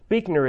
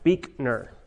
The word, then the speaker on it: Buechner: Buechner is my name. It is pronounced Buechner.